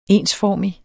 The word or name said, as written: ensformig